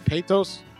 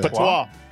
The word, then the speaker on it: patois: Patos? Pat? How do you say patois. – That? – Patois. – Patois.